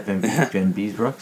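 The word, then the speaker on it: Vanbiesbrouck: And was it Van Beesbrook? Am I saying that right? – Van Beesbrook.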